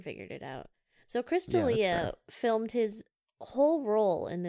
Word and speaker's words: D'Elia: Is that really how you say his name? I'm like questioning it. – Yeah, Chris D'Elia, I swear to God.